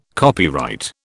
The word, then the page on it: copyright: British English